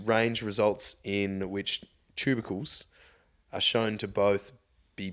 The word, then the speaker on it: tubercles: This paper examines how the addition of tubercles, how do you say that? – Tubercles. – Tubercles. Oh, it is. There's no R.